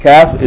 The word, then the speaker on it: Kaf: And Kaf is a little bit ahead of it. Yani, the Kaf is pronounced Ka, and the Qaf is pronounced Qa.